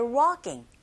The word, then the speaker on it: walking: working 아니고 walking. L 사운드는 묵음입니다. Google listening. One, grabs my attention after walking.